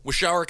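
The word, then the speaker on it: Waushara: How do you pronounce that? – Washara County. – All right, cool.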